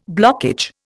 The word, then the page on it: blockage: Indian